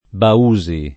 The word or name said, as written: Bausi